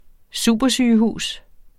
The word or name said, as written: supersygehus